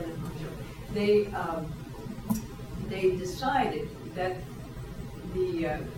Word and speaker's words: Hangzhou: Is it properly pronounced Hangzhou? – Hangzhou.